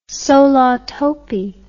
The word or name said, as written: solatopee